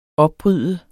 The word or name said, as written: opbryde